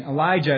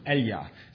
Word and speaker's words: Elijah: Now, we're not going to quite get this unless we know, I mean, Elijah is the way English speakers pronounce this, but in Hebrew it's Eliah, so it sounds like Eli.